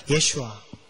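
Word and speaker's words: 예슈아 (ישוע): 약속의 땅 가나안에 들어가는 여우수아여우수아여우수아를 여러분 히브리어로 발음하면 한번 따라해 볼까요? 예슈아. 예수와. 신약에서 예수와란 이름이 바로 예수스. 따라해볼까요? 예수스. 헬라어로는 예수스, 바로 예수님의 이름과 똑같은 이름입니다.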